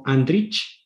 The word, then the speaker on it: Andrić: by Ivo Andrić.